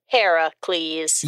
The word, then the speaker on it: Heracles: But since we're all about Greek mythology, we will use the proper original Greek pronunciation of the hero's name and call him Heracles.